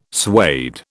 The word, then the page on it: suede: British English